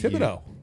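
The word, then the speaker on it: Thibodeaux: You pronounce that like DEAUX is pronounced dough in French. – Yeah. – Well, it's THIBODE. – Thibodeau.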